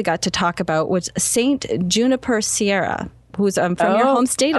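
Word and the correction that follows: Junípero Serra: Allison, you cannot mispronounce his name. Junipero Serra.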